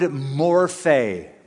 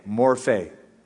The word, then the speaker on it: morphe: The word form that is used in this translation, who being in the form of God, is the Greek word morphe, morphe. We would spell it in English, M-O-R-P-H-E.